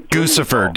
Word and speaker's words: Guccifer: Guccifer, dude. Get it right. It's Guccifer. – Yeah, obviously it rhymes with Lucifer.